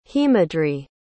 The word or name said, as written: hemadri